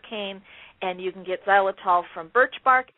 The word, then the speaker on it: xylitol: But this sugar is called xylitol. It starts with an X, and I'm going to spell it for you, X-Y-L-I-T-O-L.